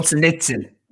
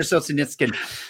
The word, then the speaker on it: Solzhenitsyn: You know, and the only way Shoshin Eshkin makes this point in his book. – Solzhenitsyn. – Alexander Solzhenitsyn.